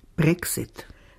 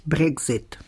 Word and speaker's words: Brexit: Anfangs sprach man es stimmlos aus. – Brexit. – Mit der Zeit setzt sich die stimmhafte Aussprache durch. – Brexit. – Diese entspricht besser der tschechischen Aussprache von Exit.